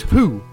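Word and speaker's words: Hu: It means how or beard or butterfly, and it's pronounced quite simply as who.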